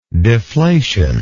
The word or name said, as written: deflationism